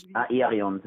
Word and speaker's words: Aerion: Das ist ein Arion-Sohn, der dann, wie wird er ausgesprochen, Arion oder wie? – Aerion sagen wir zu ihm, ja.